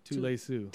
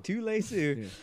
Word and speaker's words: Tuilesu: Tule Sue. Tuilesu. Tie the shoe.